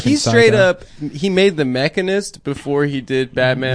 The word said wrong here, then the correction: Machinist: Machinist, bitch.